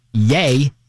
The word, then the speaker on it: Ye: So when Kanye West changed his name legally to just Y-E, pronounced yay, then, yeah, we had a little bit of issue on how that was actually pronounced. Now, so that's what we're going with? We're calling him yay? – No, we're not going with it. That's how it's pronounced.